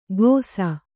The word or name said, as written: ಘೋಸ